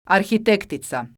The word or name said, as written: arhitektica